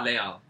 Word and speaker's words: Les Halles: And then the last place he worked at was, I'm pronouncing this terribly because I don't speak French, Leal, L-E-S-H-A-L-L-E-S. – I speak Leal. French, but I can't even... – I think it's Leal. – Leal.